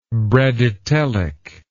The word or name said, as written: bradytely